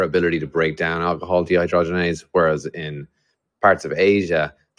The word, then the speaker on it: dehydrogenase: It's the byproduct, the alcohol dehydrogenase. I mean, I might have said that wrong. This is taking me back. – No, you said it right. Yeah, the liver converts it to alcohol dehydrogenase, which is the challenge it has to break down.